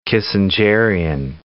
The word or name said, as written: Kissingerian